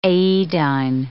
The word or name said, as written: aedine